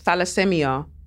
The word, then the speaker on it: thalassemia: Um, is that psi? I said P, but I think it might be thi- thalassemia.